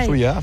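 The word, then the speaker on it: Zuia: Lo he pronunciado bien o lo he pronunciado mal, ¿eh? – Sí, Zuya, sí, genial.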